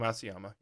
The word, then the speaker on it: Matsuyama: We had Mickelson and Matsuyama, I guess I'm pronouncing that correctly. I don't watch golf. – Hideki Matsuyama, yep.